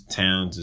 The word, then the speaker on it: Townes: Towns is spelled TOWNES and the number 17.